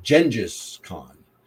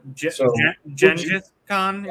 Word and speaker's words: Genghis Khan: And so would you gas or pass with Genghis Khan, or I guess correct pronunciation is actually, I believe, Genghis Khan. I've always learned it my entire life as Genghis Khan, so I did too. – That. – But I believe the correct pronunciation is Genghis Khan.